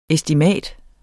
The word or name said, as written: ESTIMATETS